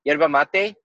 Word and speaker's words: yerba mate: And the native tea is called yerba mate. That's how it's properly pronounced.